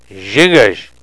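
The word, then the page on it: zhingos: Nishnaabemwin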